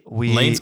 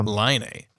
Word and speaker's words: Laine: I called him Lane, and then I've been hearing Laine. So Laine. That's yeah. That doesn't look right.